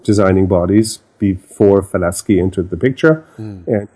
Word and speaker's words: Falaschi: By the way, most people say Falaschi. Mm-hmm. The correct pronunciation is Falaschi.